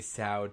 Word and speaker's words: sow: She puts on her flirtatious routine, prompting the inspector to add a sow to his list. You know, rhymes with cow.